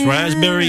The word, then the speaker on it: raspberry: K-N-E-E-L, Neil. – Raspberry, answer. – Silent letters. – Yes, silent letters. Raspberry is... – the silent S... – P. – Or P. Sorry. – Silent P, right?